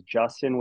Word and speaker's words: Justen: The biggest tip is remember how my first name is spelled, and it is Justin with an E-N, J-U-S-T-E-N. I'm going to say that again, J-U-S-T-E-N.